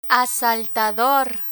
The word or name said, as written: asaltador